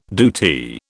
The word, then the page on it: DoT: British English